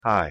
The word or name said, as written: hi